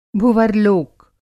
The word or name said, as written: भुवर्लोक